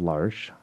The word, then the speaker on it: L'Arche: so, in, help us understand what does L'Arche do here in the city of Mobile. – Well, yeah, thank you. You do pronounce it correctly, and it is French, which is why it's called l'Arche.